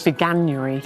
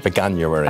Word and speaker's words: Veganuary: how do you say this? – Veganuary. – Veganuary. – I think that's which how you say it.